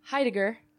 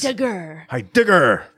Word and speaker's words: Heidegger: How would you say it? – Heidegger. – Oh, I was too heavy on the G's. – Heidegger. But that's fine. – Okay, I might even be saying it wrong. It's sure. It's Austrian.